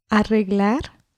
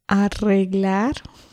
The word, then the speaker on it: arreglar: Arreglar. – Una vez más, por favor. – Arreglar. – So you need to roll your R's. It's arreglar. It's a really useful verb, and that means to fix or to repair.